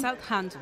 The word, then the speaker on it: Southampton: Ha ganado 1-4 el Newcastle al Southampton. ¿Cómo es? ¿Lo he dicho bien? – Southampton.